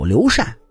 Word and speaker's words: 刘禅: 您各位也知道，刘备的儿子阿斗刘禅，他名字里的“ 禅” 字，您各位是不是经常读成“ 禅”？没错，真正的读音应该就是“ 禅”。理由呢是相当的简单。说刘禅出生之前啊，刘备领养了个儿子，取名刘封。哪个封呢？封神榜的封。有了刘禅，哎，这俩宝贝的名字合在一起就是封禅。